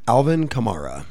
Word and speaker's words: Alvin Kamara: Alvin Kamara.